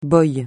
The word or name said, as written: boy